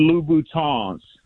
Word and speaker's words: Louboutin: And then most importantly, So the women that listen to the show don't start tagging you on YouTube, they are Christian Louboutins. You got to pronounce it with the French Louboutins.